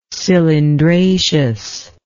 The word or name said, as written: cylindraceous